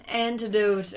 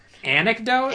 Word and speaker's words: anecdote: And he's like, oh, there's like an antidote. How do you say it? – Anecdote?